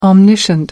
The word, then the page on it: omniscient: American English